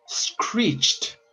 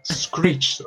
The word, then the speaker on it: screeched: La palabra monosílaba más larga del mundo en inglés es screeched o chilló, el pasado del verbo chillar, que para quien no sepa qué es monosílaba es que solo tiene una sílaba, screeched, o sea que no, no, lo puede separar como en screeched. – No. O sea, sería, sería de corrida.